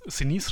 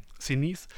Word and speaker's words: Sinise: Also wir haben zum Beispiel ähm, Gary Sinise, Sinise spricht man den so aus, mhm. Sinise, der übrigens öfter mal mit, mit Tom Hanks was gemacht hat, nämlich mhm.